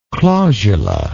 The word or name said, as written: clausula